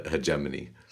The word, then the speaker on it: hegemony: I didn't teach any Chinese kids hegemony. – Hegemony. No, I just looked it up. It's a soft G, like GIF.